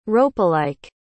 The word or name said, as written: ropelike